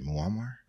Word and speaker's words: Muammar: Is that how you say it? Muammar? Yeah. Yeah, anyway, Gaddafi, they killed his infant daughter.